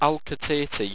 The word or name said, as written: alcatate